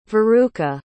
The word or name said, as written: veruca